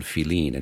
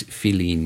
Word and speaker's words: filín: And it's, in Cuba, they spell it F-I-L-I-N, which is the way it sounds in Spanish, or how it's spelled in Spanish.